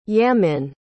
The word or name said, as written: yamin